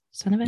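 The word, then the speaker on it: Stenavich: So then because of that, Adam Stenovich, is that how you pronounce his last name? Stenovich? – Yes.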